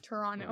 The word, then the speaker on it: Toronto: Toronto. Right? Yeah. Toronto. no T. yeah. The T is silent.